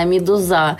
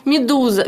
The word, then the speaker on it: медуза: Есть такая песня прекрасная «Медуза». И вот моя дочка думает, что правильно говорить «Медуза». И она меня поправляет, когда мы с ней смотрим книжки. Я говорю: дочь, кто это? «Медуза», «Медуза».